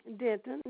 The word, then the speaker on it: Denton: My first name is Mary Denton, D-E-N-T-O-N.